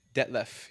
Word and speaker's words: Detlef: Is it Detlef? Det, Detlef.